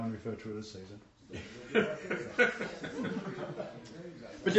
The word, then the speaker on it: Caesar: You know, it's rather interesting, we say Caesar. You know, the original pronunciation was Caesar. It doesn't sound right, does it? Hail Caesar! No, it doesn't sound right. But it's the same as Cicero, you know. It was Cicero, a hard C.